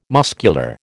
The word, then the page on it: muscular: American English